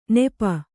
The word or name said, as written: ನಿಪ